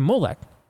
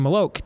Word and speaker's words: Moloch: to Molech, or to Moloch, depending on which pronunciation you go with, they didn't do that, Todd, under the expectation that nothing would happen to their favor.